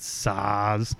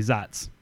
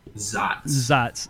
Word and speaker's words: Saaz: Zots is, anytime you see S-A-A-Z, you want to pronounce it Saz, it's actually Zots. – Zots.